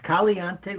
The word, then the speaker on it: Caliente: But I wanted to ask you a little bit more about your, and help me pronounce this, Caliente Leadership. – Love it. Yeah. Yes, it's Caliente Leadership. Caliente, as you people may know, is Spanish for hot.